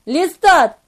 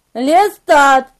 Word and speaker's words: Лестат: «Лестат!» – произнес я как можно отчетливее. «Лестат!» – с ударением на втором слоге и четким конечным «Т».